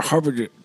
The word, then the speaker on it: harbinger: I heard him say, that's a harbinger. Did I say that right? Yeah.